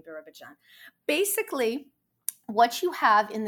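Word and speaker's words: Birobidzhan: It's Birabidzhan. That's the pronunciation of it.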